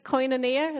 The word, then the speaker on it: koinonia: It's koinonia.